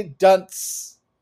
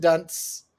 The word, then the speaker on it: Dunst: You say dunce because Dunst. It's a T. Like, you're adding a D sound at the end. – Kirsten. – That you don't need to.